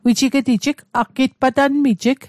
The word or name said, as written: patanmit